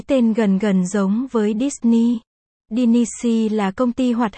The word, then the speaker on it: Disney: Gốc ở đây là Dinisi, Dinisi, Disney bên đó phát âm là Disney, không biết có liên quan gì đến nhau không nữa.